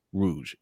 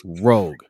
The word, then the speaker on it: Rogue: The Rogue Pod, R-O-U-G, is not pronounced Rouge. It is pronounced Rogue.